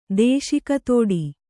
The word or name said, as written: ದೇಶಿಕತೋಡಿ